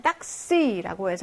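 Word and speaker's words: taxi: 2번에는 딱시라고 해서 이때 익스는 크스 발음으로 나는 거죠.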